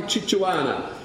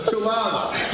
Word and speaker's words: chihuahua: Kimberly said that she would fight like a chichuana. Fight like a chichuana. A chichuana. I stand corrected on the pronunciation of the dog. She would fight like a chichuana. Like a chichuana. For a chihuahua.